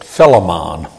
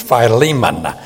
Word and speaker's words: Philemon: There was an old song many years ago that pronounced it Philemon. Nope, it's Philemon.